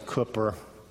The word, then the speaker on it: Cowper: And I know it looks like Cowper, but I think usually are pronounced a Cooper.